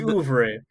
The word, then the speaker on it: oeuvre: That important part of Eddie Murphy's oeuvre. – That's how you say it? – Uh-huh. – His oeuvre.